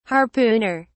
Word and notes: harpooner: British English

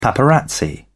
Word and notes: paparazzi: British English

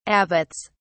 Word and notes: abbots: British English

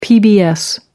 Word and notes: pbs: American English

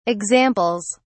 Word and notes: examples: British English

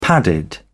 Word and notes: padded: British English

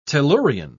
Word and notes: tellurian: British English

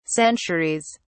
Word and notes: centuries: British English

